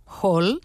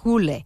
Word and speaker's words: Hole: Si vosaltres, com jo, sempre l'heu anomenat Hole, sapigueu que en noruec i en danès es pronuncia Hole.